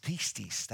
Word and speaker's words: pistis: In Greek is pistis. That's how you pronounce faith.